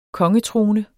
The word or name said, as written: kongetrone